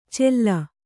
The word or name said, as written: ಚೆಲ್ಲ